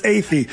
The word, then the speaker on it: Athey: Well, people say, how do you pronounce that? And I say, well, it sounds like atheist, but we are not atheists. – That's right. – But it's Athey.